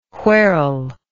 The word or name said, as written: wherell